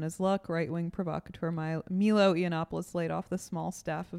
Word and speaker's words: Yiannopoulos: Yiannopoulos. Milo. – Yiannopoulos.